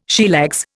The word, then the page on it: sheerlegs: Indian